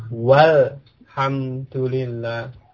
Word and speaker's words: walhamdulillah: Sekarang dari tadi, alhamdulillah, tambahkan wawu sebelumnya. Bagaimana membacanya? Walhamdulillah. Ya. Alifnya tidak diucapkan. Jadi langsung ke kelamnya. Ya, walhamdulillah, ya kan?